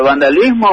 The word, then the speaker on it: vandalism: Todo el mundo me pregunta, ¿vandalismo o vandalismo? – Es vandalism.